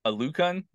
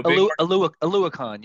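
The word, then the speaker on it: Oluokun: How do you say this guy from Jacksonville, the linebacker? – Aluakon? – Aluakon? There we go. – Aluakon, yeah. – Aluakon. There you go.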